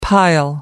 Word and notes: pile: American English

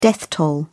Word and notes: death toll: British English

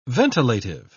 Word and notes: ventilative: British English

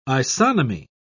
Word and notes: isonomy: British English

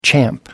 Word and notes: champ: American English

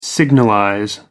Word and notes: signalize: American English

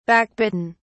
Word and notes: backbitten: British English

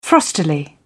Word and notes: frostily: British English

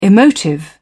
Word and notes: emotive: British English

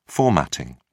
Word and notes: formatting: British English

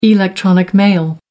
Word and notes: electronic mail: American English

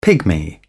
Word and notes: pygmy: British English

